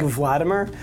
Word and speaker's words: Vladimer: I'm at Mike Vladimer. My last name is spelled a little unexpectedly, so it's V-L-A-D-I-M-E-R. That's the important thing to know.